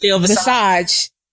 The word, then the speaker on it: Visage: Yes, Visage.